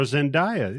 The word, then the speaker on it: Zendaya: Yeah, more so Zendaya. Okay. I hope I'm saying her name right. – More Zendaya.